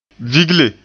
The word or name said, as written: vigle